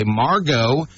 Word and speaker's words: Margot: We have one Saturday birthday, Margot Nicholson. The T is silent. That's what I like about the name Margot.